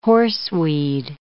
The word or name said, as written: horseweed